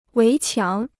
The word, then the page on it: 围墙: wéi qiáng